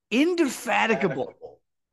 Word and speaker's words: indefatigable: Indefatigable.